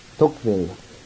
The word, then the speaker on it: Tocqueville: Tocqueville, thank you.